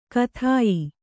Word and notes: ಖಾದ್ಯ: khādya

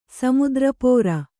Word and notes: ಸಮುದ್ರಪೋರ: samudra pōra

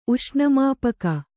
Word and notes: ಉಷ್ಣಮಾಪಕ: uṣṇamāpaka

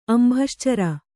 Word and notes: ಅಂಭಶ್ಚರ: Kannada

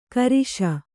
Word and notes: ಕಾರೀಷ: Kannada

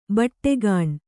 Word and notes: ಬೈಠಕ್‍ಕೋಣೆ: kannada; baiṭhak kōṇe

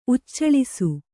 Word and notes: ಉಚ್ಚಳಿಸು: kannada

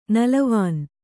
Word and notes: ನೆಲವೆಣ್ಣು: kannada

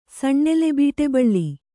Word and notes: ಸಣ್ಣೆಲೆಬೀಟೆಬಳ್ಳಿ: kannada

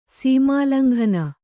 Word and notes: ಸೀಮೋಲ್ಲಂಘನ: kannada

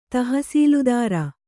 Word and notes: ತಹಸೀಲುದಾರ: tahasīludāra

